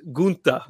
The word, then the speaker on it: Gunther: So the German Gunther? Pronunciation is Gunther.